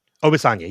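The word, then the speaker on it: Obisanya: And Sam Obasanya, did I say that right? – Obasanya, yes.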